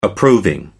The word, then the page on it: approving: American English